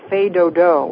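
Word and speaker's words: fais do-do: Actually, the tradition, and it's what we call the last evening dance, is called a fait dodo, which is spelled F-A-I-S, and then D-O and D-O, which very loosely translated means put the kids to sleep.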